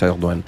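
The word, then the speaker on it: Erdoğan: Ah oui, j'ai vu un G, Erdogan, ça se prononce Erdogan.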